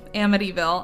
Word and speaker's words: Amityville: Amityville? – Yes, thank you. Amityville.